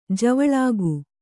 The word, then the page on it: ಜವಳಿಕ: kannada